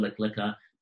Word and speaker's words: Lech Lecha: And so tonight we are going to go through not all of Parsha Lech Lecha, but we're just going to go through the very first portion of it. And Lech Lecha is spelled, it's 2 words, L-E-C-H space L-E-C-H-A.